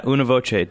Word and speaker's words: Una Voce: But you're here today with your involvement with, it's Una Voce, right? Is that how I pronounce it? – Yeah, Una Voce, Des Moines, yeah.